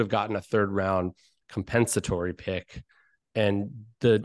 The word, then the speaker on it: compensatory: How do you pronounce that word? Compensatory? Doesn't sound right. – Compensatory. – Yep. There you go.